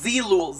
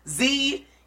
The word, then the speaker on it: Zeelool: No, it's Zilul. Zilul. – Are you saying that right? – I swear I am. Zeelool. I think that's it.